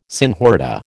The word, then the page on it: senhorita: American English